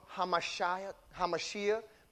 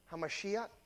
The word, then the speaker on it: Mashiach: It's how we pronounce that, Yahshua HaMashiach, but it's Ach at the end. You see? HaMashiach.